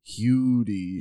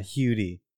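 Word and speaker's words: Huddie: So I was looking at the pronunciation for this. It said like H-Y-O-O. Like, yeah, Hugh. – Hudy. – Yeah, Hudy.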